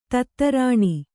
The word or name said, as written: ಧಾತುರೇಣು